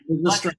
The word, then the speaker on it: Lysistrata: I can't even say it right. – Lysistrata. – Thank you.